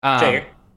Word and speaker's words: Shager: And when you got a young guy like Braden Shager? Am I pronouncing that right? – Shager.